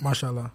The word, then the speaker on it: Mashallah: Mashallah.